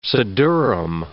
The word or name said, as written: siddurim